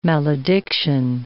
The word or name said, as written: malediction